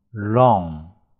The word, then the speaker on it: wrong: ask 邀请，wrong 错误的，注意 w r 组合里面 w 不发音，z o 嗯 wrong 要卷舌头，information 信息。